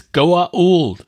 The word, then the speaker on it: Goa'uld: So did you notice that some of the actors can manage to say Goa'uld and some of them can only say Gould? – Yes. – I find that to be funny. I don't know why they didn't like cut. Dude, it's Goa'uld.